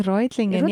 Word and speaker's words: Reutlingen: Ja, also war im Rötlingen. – Aha, in Reutlingen.